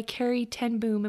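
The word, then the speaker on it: Corrie ten Boom: I love, there's a story by Carrie Ten Boom.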